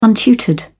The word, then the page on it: untutored: British English